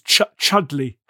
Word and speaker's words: Chudleigh: Chudleigh.